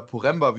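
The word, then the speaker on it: Poręba: Dann verlässt wahrscheinlich Lukas Poreba, oder Poremba, wie ich mal gehört habe, wie es ja eigentlich ausgesprochen wird. – Ja, korrekt.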